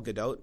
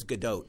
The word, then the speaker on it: Gadot: Real quickly, I did some research on Gal Gadot because I wanted to make sure. Oh, Gal Gadot. – It's Gal. – Yeah, but it's Gadot. – No. – You say the T at the end.